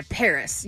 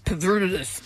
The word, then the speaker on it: PVRIS: I picked a band that I've liked for a while, but this song is brand new by Paris. You might know them. – Paris Hilton? – By the way, they spell it P-V-R-I-S, so people might think, oh, maybe it's Pavrus. It's not.